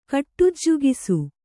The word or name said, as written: ಕಟ್ಟುಜ್ಜುಗಿಸು